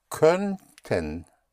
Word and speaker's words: könnten: In German, you put the umlaut over the O of konnten, and you will get könnten. Könnten. Ö, like in sir, like in fur.